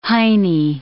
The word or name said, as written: heinie